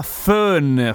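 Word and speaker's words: Föhn: Il fon viene dal vento caldo che si chiama fön. Fön si scrive in tedesco F-O-H-N con l'umlaut, i due puntini, la metafonesi sulla O. Fön.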